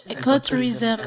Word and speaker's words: ecotourism: Iya, di tengahnya juga. – Di bagian sama tournya. – Tournya. Ecotourism, tour. Yes. Yeah. – Jadi kita pronounce-nya ecotourism.